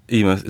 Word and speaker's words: Eimear: Eimear. – Eimear.